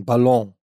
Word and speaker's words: Ballon: Du musst Französisch aussprechen. Ballon.